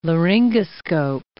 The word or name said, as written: laryngoscope